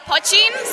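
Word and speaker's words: poitín: But poitchine? – It's actually poitchine, so it's an Irish word for little pot or a pot still, which is distilled in.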